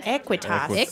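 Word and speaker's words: Aequitas: And am I saying this right? Aquitas? Equitas? – Equitas. – Equitas Veritas. – A-E. Yeah.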